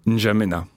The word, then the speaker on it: N'Djamena: N'Djamena. – N'Djamena.